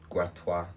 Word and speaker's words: Gratiot: Gratiot. Gratiot is supposed to be a French word. – Gratois.